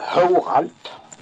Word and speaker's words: Håralt: Jo, det uttalet på den byn det är Håralt uttalas den. Och inte byggar något på huvudet, Håralt.